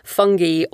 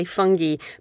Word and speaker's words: fungi: So in Britain, we say fungi, but Americans say fungi. So just to be clear, if you Google that, you will find that there are two official pronunciations.